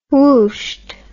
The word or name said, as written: whooshed